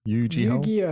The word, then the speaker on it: Yu-Gi-Oh: Valhalla dropped a place, and coming in the end is Yu-Gi-Ho Master Duel. – Yu-Gi-Oh. – There you go.